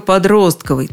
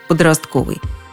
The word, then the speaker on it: подростковый: Только подростковый, твердил словарь ударения Агейнка Язарова всех изданий до 2000 года. На ударении подростковый настаивал и орфоэпический словарь под редакцией Аванесова, хотя и допускал вариант подростковый.